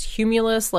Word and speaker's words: humulus: I cast it on, and we're thinking it's pronounced humulus, like cumulus, like a cloud.